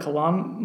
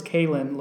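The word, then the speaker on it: Kay'Lynn: It looks to me like Kalan LeBourne or Kalane LeBourne. It's Kaylin LeBourne. K-A-Y apostrophe Lynn, like Lynn, the girl's name, Lynn.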